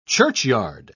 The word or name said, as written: churchyard